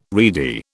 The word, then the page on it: reedy: British English